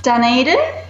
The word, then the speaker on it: Dunedin: Um, Dunedin.